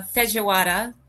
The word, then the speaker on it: feijoada: Um, so, in fact, I wanted to explain today how to make a, a feijoada. Did I say that correctly? Feijoada. Feijoada. feijoada. – Yes.